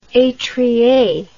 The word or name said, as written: etrier